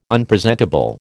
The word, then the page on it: Unpresentable: American English